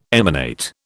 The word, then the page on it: emanate: British English